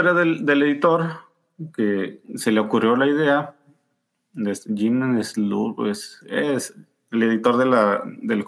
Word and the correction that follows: Salicrup: te digo acá, Jim Salicrup. – Ajá. – Sí, lo estoy pronunciando bien, pero mis cojones, porque los gringos pronuncian mal el español, así que...